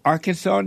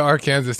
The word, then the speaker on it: Arkansas: I mean, it's still Arkansas to me because how come Kansas is spelled Kansas, pronounced Kansas, but you put an AR in front of Arkansas and it's Arkansas?